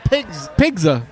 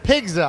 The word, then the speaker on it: Pigzza: We're here at Pigzza. Am I saying that right? – Pigzza. – Pigzza.